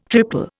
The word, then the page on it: triple: Indian